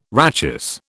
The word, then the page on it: rachis: British English